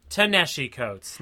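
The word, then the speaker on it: Ta-Nehisi Coates: I'm going to say it wrong. – Nateshi Coates? – Do you know Nateshi Coates? I'm pretty sure I'm saying it wrong. – Give me a second. – Tanashi Coates, not. – Okay. – I took the ta and stuck it in the middle of his first name, like an asshole.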